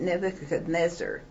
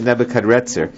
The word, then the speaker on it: Nebuchadnezzar: I notice you pronounce it Nebuchadnezzar with an N. That's the way I was always taught. But I noticed here it's an R. – Yes. – Can you tell me why? – Okay, it's spelled both ways.